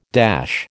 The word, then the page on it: dash: American English